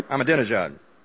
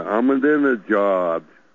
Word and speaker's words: Ahmadinejad: Ahmadinejad. – Yeah, Ahmadinejad.